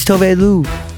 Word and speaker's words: Tove Lo: I said her name wrong in the interview. Don't judge me. It's Tove Lu.